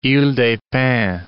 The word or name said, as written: Ile des Pins